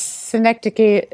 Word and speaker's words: synecdoche: You've done a great job, and you pronounce synecdoche at least the way I was taught that it should be pronounced.